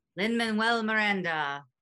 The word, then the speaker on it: Lin-Manuel Miranda: Lin-Manuel Miranda? – Lin-Manuel Miranda.